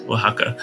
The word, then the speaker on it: Oaxaca: Yes, Oaxaca.